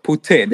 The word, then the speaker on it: poutine: That's the correct way. That's the French way of saying it. Oh, okay. Poutine.